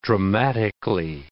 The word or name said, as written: dramatically